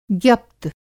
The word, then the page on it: ज्ञप्त: Hindi